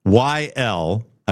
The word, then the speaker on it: Weckl: See if you can find Dave Weckl, Weckyl. I believe that's the pronunciation of it.